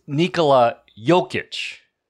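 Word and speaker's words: Nikola Jokić: For the 100th time, you guys keep saying Nikola Jokic. – Yeah, that's his name, bro. – That's his name, bro. – No, it's not. It's Nikola Jokic.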